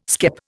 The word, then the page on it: skep: Indian